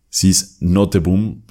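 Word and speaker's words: Nooteboom: Eh, Sis Noteboom es como se pronunciaría en español, que la lectura de poesía es un oficio.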